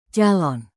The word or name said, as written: calon